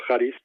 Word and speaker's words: Charis: Caris significa gracia en griego, pero al mismo tiempo se escribe Charis, ¿no? pero se, se dice Caris o Charis, y además es un acrónimo, es decir, cada letra tiene, o sea, pues significa una palabra, ¿no?